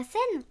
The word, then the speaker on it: Cène: La scène? – Oui, tu as bien retenu ce mot qui s'écrit C-E-N-E.